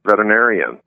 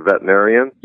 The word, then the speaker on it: veterinarian: And two, I got to give you a compliment about pronouncing veterinarian. So almost everybody when I'm on an interview, almost everybody forgets the one symbol. They say veterinarian. – Yeah. – So that it's like veteran, you know.